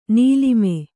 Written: ನೀಲಿಮೆ

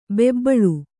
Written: ಬೆಬ್ಬಳಿ

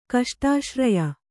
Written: ಕಷ್ಟಾಶ್ರಯ

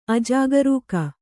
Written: ಅಜಾಗರೂಕ

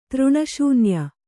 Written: ತೃಣಶೂನ್ಯ

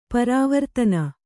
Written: ಪರವರ್ತನ

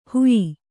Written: ಹುಯ್ಯಿ

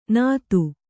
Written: ನಾದು

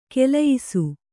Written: ಖುಲಾಯಿಸು

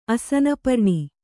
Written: ಅಸನಪರ್ಣಿ

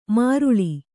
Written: ಮಾರುಳಿ

